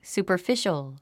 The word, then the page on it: superficial: sùːpə r fíʃəl; スーパァフィショウ